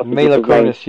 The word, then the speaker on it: Mila Kunis: And the video for this one had, what, is it Mila Kunis? Is that how you say her name? – Mila Kunis, yeah, that's right.